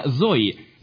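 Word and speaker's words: зои (ζωή): И оба греческих слова, и жизнь Бога, или вечная жизнь, произносится «зои». «З-О-И», «зои», «жизнь».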